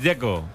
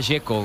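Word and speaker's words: Džeko: Porque de Z, ¿cómo se pronuncia? – Dzeko. Bruno dice que es Dzeko. – Como tirando a J, Dzeko o algo así.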